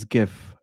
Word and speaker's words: GIF: And lastly, thank you, QW, for pronouncing it as GIF and not JIF.